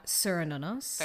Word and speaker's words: Cernunnos: Cernunnos. – Very nice. – I apologize if that's not the actual Celtic pronunciation or how anybody else pronounces it, but that's how I've heard it pronounced.